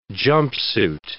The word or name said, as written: jumpsuit